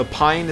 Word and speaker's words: Pijn: So Pyne is spelled P-I-J-N, for those of you who don't know.